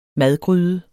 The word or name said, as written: madgryde